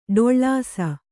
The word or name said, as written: ಠಾಳಿಸು